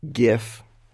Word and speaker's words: GIF: We at Giphy are staunchly GIF. Mm-hmm. We say hard G's for hard G's.